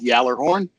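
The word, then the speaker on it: Gjallarhorn: It's from the Swedish origin, but it's called Jallarhorn. That's G J A L L A R H O R N.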